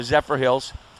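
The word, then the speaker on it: Zephyrhills: That takes you all the way up to Zephyr Hills.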